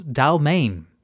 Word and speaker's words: D'Almaine: This is Charles Dalmaine. That's spelled D'Almaine.